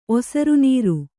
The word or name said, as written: ಒಸರುನೀರು